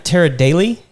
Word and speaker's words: Tara Daley: Tara Daly? – That's correct. – All right, Tara Daly from Design Works.